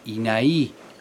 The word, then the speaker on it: INAI: Ha llegado aquí el director de tierras del INAI. Acabo de corroborar que se dice así, con acento en la I latina final.